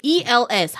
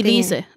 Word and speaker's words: Els: Anyway, it's pronounced Els.